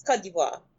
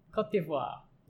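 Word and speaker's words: Côte d'Ivoire: It's Cote d'Ivoire. Cote d'Ivoire.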